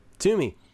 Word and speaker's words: Twomey: T-W-O-M-E-Y. Twomey? You guys taking a stab at that? – The guy I know whose last name is spelled like that is Toomey. – Toomey. Jared Toomey. There you go.